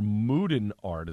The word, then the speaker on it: Mooden Art: But kicking it off on Suds and Duds, Modern Art, or Mooden Art is the way they spell it, M-O-O, Moo, for Bolero Snort.